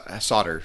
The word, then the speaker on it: solder: Yeah, people just say solder. It's generally how we pronounce it.